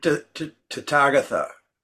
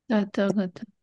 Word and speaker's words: Tathagata: Tathagata. – Tathagata.